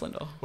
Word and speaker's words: Flindel: Yeah, it's pronounced Flindel.